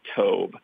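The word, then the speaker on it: tob (טוב): And in the Hebrew derivation, the word good is a word pronounced tob, and it's translated as good in our English Bibles.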